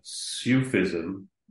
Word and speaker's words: Sufism: Um, before we get to all the, the writings, um, what is uh, Sufism and why is Avram ben Aram associated with Sufism, if I'm pronouncing it right, even? – Yeah, that's the right way.